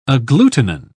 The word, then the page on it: agglutinin: British English